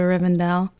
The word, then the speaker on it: Rivendell: I mean, I would totally go to Rivendell.